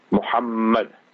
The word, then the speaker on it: Muhammad: Whenever you pronounce it, you find both your lips will touch. One is the beautiful name of Rasulullah sallallahu alayhi wa sallam, Muhammad.